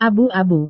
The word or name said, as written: abu-abu